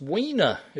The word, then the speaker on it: Weiner: I was reading the guy's name as Weiner. German E-I is pronounced I. If a guy's name is written S-T-E-I-N, we call him Stein, not Steen. Or if we don't, we should.